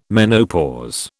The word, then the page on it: menopause: British English